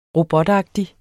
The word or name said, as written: robotagtig